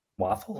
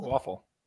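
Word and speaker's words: Wafle: Owen Waffle. – Waffle. – Waffle. All right. So it sounds exactly how it looks, right?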